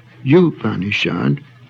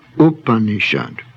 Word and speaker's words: Upanishad: In Sanskrit, the initial U sound is always short and should not be pronounced as the English word Y-O-U, therefore not Upanishad, but Upanishad. This term may be rendered esoteric doctrine, since the Upanishads represent interpretation of the Vedas by the Vedanta methods.